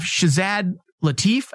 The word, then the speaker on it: Shazad Latif: First off, Shazad Latif, I think is how you say his name. – Yes.